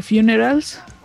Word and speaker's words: Funerals: Ellos eh, son parte de la banda de Funerals.